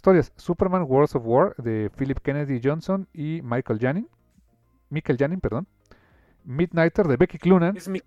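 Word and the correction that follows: Mikel Janín: Perdón, se pronuncia Miquel Janin, español.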